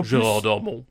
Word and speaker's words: Gérard Darmon: Et, et en Gérard Darmon.